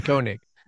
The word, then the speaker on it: Koenig: It's Koenig.